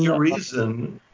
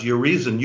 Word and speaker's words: Urizen: Urizen. Yeah, maybe pronounced Urizen. U-R-I-Z-E-N. I think it is.